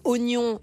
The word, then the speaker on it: oignon: J'entends par là moins d'accent circonflexe, moins de tirés, enlever des lettres, par exemple, oignon, ce fameux i qui visiblement ne sert à rien en termes de prononciation, on va l'enlever directement à l'écrit aussi.